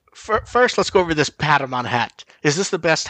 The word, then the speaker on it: Patamon: It's a cream colored hat with the very nice Patamon embroidering.